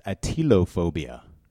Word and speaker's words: atelophobia: And the phobia of imperfection is atelophobia. Atelophobia. Say it with me, audience. – Atelophobia. – Is it spelled like Attila the Hun? – No, it's A-T-E-L. – A-T-E-L, okay.